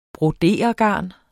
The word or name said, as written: broderegarn